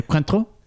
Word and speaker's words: Cointreau: You are the French-Canadian, so I should be asking you how it's pronounced. – I'm trying to expand your mind here. Cointreau? – That's what I said.